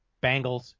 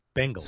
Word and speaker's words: Bengals: How do you pronounce Bengals? Because I've heard it Bengals, Bangles, and Bingles. – Bengals. – That's it. Bengals. – Cincinnati Bengals. – Bengals. – Am I saying it correctly? – I think you're saying it correctly. – Cincinnati Bengals. – Bengals. But there's an E in there. Bengals. – That is an E. – Bengals. – Bengals. Not Bangles.